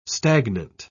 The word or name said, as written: stagnant